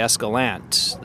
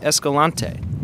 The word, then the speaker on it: Escalante: And even how you pronounce the name of the town can be loaded. Longtime locals say Escalante. The newer folks running the organic bakeries or eco-tourist companies may pronounce it Escalante.